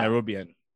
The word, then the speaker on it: Nairobian: Nairobian.